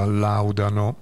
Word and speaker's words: Laudano: a Tiz che non sapevo come si pronunciava Laudano oppure Laudano, non ricordavo, e Tiz mi aiuta dicendo che si pronuncia Laudano.